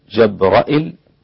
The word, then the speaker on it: جبرائيل: اللي بيقرأ جبريل جبريل، جبرائيل جبرائيل، هكذا.